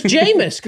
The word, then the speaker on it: Jameis: Amos? – Rhymes with famous Jameis.